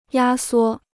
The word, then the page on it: 压缩: yā suō